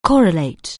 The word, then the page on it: correlate: anglais